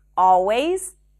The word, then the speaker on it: always: The next hack is that you don't have to pronounce the L in the words always, all right, and already. It's not a mistake to pronounce the L, but if you're struggling with the L, just drop those sounds. Simply pronounce an ah sound right before.